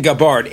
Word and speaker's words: Gabbard: I'm saying Gabbard. It's an English name.